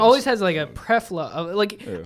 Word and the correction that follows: plethora: I think plethora.